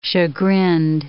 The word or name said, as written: chagrined